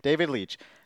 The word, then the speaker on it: Leitch: How do you pronounce his name? – David Leitch.